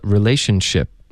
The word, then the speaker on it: relationship: 嗯，宣 ch ch ch，这里又来了一个去哪儿的 ch， relationship， OK，所以一个句子中呢，出现一模一样的这个发音技巧两次，然后重点就是因为前面的音，你的舌尖得顶上颚，连发到后面的摩擦音呢，就导致一个爆破， OK，然后这里如果 relationship 你听别人说的时候不熟悉这个发音技巧呢，你可能也许听到 chip 这个字了、也就是饼干、薯片，对吧？ chip，因为它发音差不多是一样的。 relationship OK，就是这么发的。